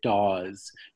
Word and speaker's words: Dawes: Who is the founder, I think he pronounces it Dawes, George Dawes Green.